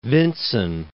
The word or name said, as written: Vinson Massif